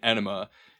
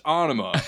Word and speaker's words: Anima: And he keeps correcting the guy, saying the name, calling it Enema. And he's like, no, listen, it's my word. I made it up. It's pronounced Anima.